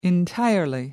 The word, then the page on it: entirely: American English